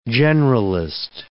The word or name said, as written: generalist